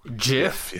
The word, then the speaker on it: GIF: GIF? – Yes, that's how it's pronounced.